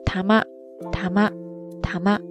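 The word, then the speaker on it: たま: 汉字写作毛玉，毛发的毛，再加上玉石的玉，在这儿玉石的玉本来呢是读作 tama，tama，tama，ですね。在这儿呢就是浊音化了，它的意思是球。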